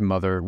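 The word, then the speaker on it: Mothxr: Yeah, so it's just Mother. We had to add the X because there are a couple other mothers who gave us a cease and desist.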